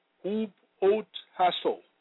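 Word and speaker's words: hupotasso (ὑποτάσσω): The Greek word for subject in the above text of Scripture is pronounced hupotasso, meaning to subordinate, to obey, to be under obedience, and to put under, subdue unto, be put in subjection, submit self unto.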